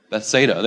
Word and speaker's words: Bethsaida: It says, immediately Jesus made his disciples get into the boat and go ahead of him to Bethsaida. Yeah, I can't read. How do you say that, Pastor? Bethsaida, there it is.